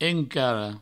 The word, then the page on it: encara: encaro